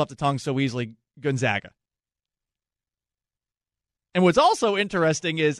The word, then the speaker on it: Gonzaga: I've always said Gonzaga correctly, but if the school is named after Aloysius Gon Gonzaga, that's how you're supposed to pronounce it. That's how it's pronounced in Italian.